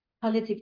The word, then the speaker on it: palliative: Palliative, is that how you say it? Palliative care? – Yes, yep.